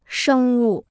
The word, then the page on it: 生物: shēng wù